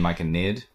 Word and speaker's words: Myconid: I Your writing prompt for today is Myconoid. Because I'm looking at a Myconoid mini over there. – Do you mean Myconid? I haven't wanted to correct you, but you've been saying it wrong.